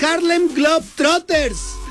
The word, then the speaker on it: Harlem Globetrotters: Oye, ya nos vamos y hubo ganadores de los Harlem Globetrotters. Ahí está, mira, pero mira, no pude Perlas Negras, pero los Harlem Globetrotters sí lo dije bien.